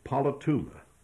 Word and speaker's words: politeuma: Now, the Greek word here is polituma. Let me spell that for you. P-O-L-I-T-E-U-M-A.